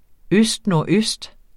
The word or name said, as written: østnordøst